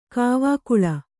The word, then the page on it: ಕಾವಕೂಲ: kāvakūla